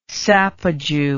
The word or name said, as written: sapajou